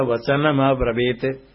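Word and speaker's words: अब्रवीत्: ब्रवीत बोलते समय ब्रवीत तकार उच्चारण होना चाहिए, तो हे राजा वचनमब्रवीत,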